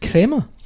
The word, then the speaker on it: crema: Is it pronounced crema? – Yeah.